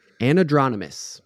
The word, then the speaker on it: anadromous: And now, anadromous. That's how you say it.